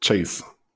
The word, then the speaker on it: Chase: Das heißt, es liegt sehr, sehr nahe der Verdacht, dass er nicht Charles, wie ich ihn japanisch ausgesprochen habe, sondern Chase ausgesprochen wird.